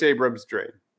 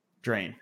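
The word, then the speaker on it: Abrams-Drain: And then tell Chris Abrams Drain. – Drain. Okay. Just like it looks then.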